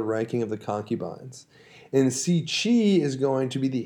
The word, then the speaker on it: Cixi: It's spelled C-I-X-I, okay. But I believe it is pronounced Cixi.